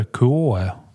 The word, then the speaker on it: KUOE: Själva klockmärket heter Koe. Reserverar mig för uttalet. Q, eh, förlåt, KUOE. Eh, och den tillverkas i Kyoto.